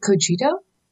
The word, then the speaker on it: Cogito: Is it Cogito? – That's it.